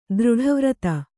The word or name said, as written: ದೃಢವ್ರತೆ